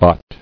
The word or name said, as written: Bots